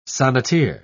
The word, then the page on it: sonneteer: British English